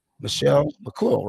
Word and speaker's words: Michelle McCool: Michelle McCool. I might say it wrong. Michelle McCool, right? – Yeah, you got it.